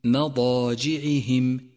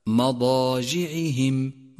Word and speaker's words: مضاجعهم: ثالثا، انتبه إلى ترقيق الميم في كلمة مضاجعهم، مضاجعهم، فهي حرف مرقق دائما، ولكن يخشى من تفخيمه إذا جاء بعده حرف مفخم، أي هكذا مضاجعهم ما، والصواب ما مضاجعهم.